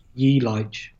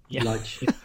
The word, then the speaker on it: Yelich: I really like Kristen Yelich or Yelich. I don't know how to pronounce his name. They're a fun team, though. – Ye Lige. – Oh, it's Yelich. Okay. – Ye Lige.